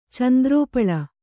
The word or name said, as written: ಚಂದ್ರೋಪಳ